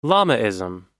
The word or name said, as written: Lamaism